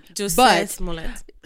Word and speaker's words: Jussie Smollett: Jussie Smollett.